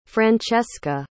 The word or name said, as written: francesca